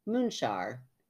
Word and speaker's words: Munxar: she gives me the pronunciation, Munxar, spelled M-U-N-X-A-R. I always like to put the spelling in there because that just blows my mind that M-U-N-X-A-R would be pronounced Munxar at St. Thomas Bay.